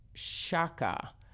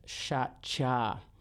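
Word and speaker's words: Shaqja: Let's hear from Dervish Shaka or Shakcha. I had to look up how to pronounce the Albanian alphabet. Or it's a Latin alphabet, but they have a lot of Q's and X's. So again, the name of the artist is Dervish Shakcha.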